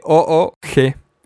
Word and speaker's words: Moog: Esa banda lo conoce como Moog, porque se escribe con doble O, Moog, es Moog.